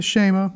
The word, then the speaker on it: Shema: Shema? – The Shema.